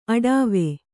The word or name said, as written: ಅಡಾವೆ